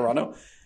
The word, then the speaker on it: Carano: Carano.